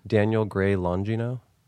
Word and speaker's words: Daniel Gray Longino: This guy, Daniel Gray Longino. He directs like Kroll Show, Portlandia. – Wait, say his name again? – Daniel Gray Longino. – How old is this guy? – I don't know, mid-30s. L-O-N-G-I-N-O.